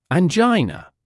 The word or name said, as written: angina